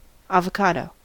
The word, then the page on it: avocado: American English